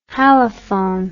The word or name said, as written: halaphone